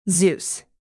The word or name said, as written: zeus